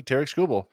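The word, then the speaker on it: Tarik Skubal: Someone who rhymes with Merrick Newbel. – Yes, it could very well be Tarek Skubal.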